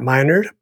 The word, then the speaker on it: Minard: My name is Dr. Matt Minard, pronounced my nerd, rightfully so, and I am tickled to be your host.